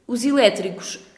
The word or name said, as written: os eletricos